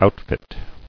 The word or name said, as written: Outfitter